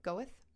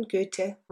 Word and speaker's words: Goethe: G-O-E-T-H-E. Goethe.